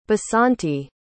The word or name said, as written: basanti